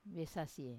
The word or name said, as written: vesasie